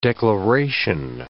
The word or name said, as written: declaration